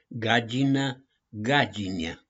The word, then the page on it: GAGINĂ: GAGINIE